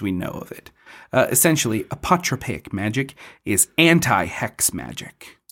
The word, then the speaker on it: apotropaic: It's your word of the week. Apotropaic. – Apotropaic. – Apotropaic magic. – Will you spell it? – A-P-O-T-R-O-P-A-I-C.